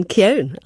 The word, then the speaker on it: Keown: Appreciate you coming in to talk to us about On the Edge and about Manchester United, Martin Keown, as it is pronounced, as pleasure.